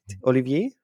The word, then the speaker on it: Olivier: Und dann gibt es noch ein Interview mit dem Komponisten Oliver Derivier, habe ich das richtig gesagt, von Dying Light the Beast? Olivier? – Ja, genau.